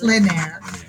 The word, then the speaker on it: plein air: It's plein air.